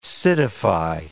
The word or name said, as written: citify